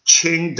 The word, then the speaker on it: Qing: Qing Dynasty. You know, it rhymes with R-A-N-G, ring, S-I-N-G, sing. The old spelling was C-H-I-N-G, Qing.